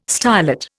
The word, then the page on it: stylet: Indian